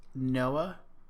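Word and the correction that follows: Nioh: I always say it wrong. – Neo? – Neo. God damn it.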